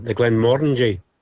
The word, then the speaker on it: Glenmorangie: I'm trying not to correct you, but the Glenmorangie.